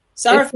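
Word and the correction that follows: Safaree: Safari. – Yeah, you said Mr. Sarafay. Like, what's wrong with you? – Safari. Do you know what? I put the R before the F.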